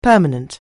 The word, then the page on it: permanent: anglais